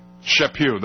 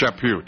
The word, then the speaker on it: Chaput: I never can pronounce his name correctly. Chaput, that's right, it's Chaput, Charles Chaput.